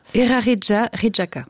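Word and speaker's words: Eraritjaritjaka: Oui, Erari Chari Chirka. Quelque chose comme ça? Vous voulez me le dire à ma place? – Erarija Rijaka. – C'est mieux.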